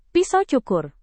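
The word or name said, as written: piyama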